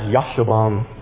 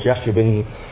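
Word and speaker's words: Jashobeam: First one you read about is supposed to be pronounced Yashobam, but we live in East Texas, and Mr. Harrell talked about the whole pronunciation thing, so I call him Jashobam.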